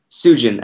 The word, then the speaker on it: Sujan: Yeah, actually, I should have just asked you, am I pronouncing this Sujan or Sujan? – Sujan, actually. – Sujan, there we go.